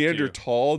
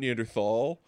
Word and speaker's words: Neanderthal: it's actually Neanderthal, not Neanderthal. Yeah.